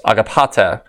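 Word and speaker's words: agapate (ἀγαπᾶτε): The word in Greek is pronounced agapate.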